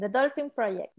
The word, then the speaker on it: Dolphin Project: Bueno, él creó Dolphin Project, lo pronuncié bien. The Dolphin Project.